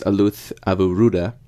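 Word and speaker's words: Aluth Avurudda: So the Sinhalese New Year, which is pronounced Aluth Avuruda, marks the end of the harvest season and is held on the 13th or 14th of April.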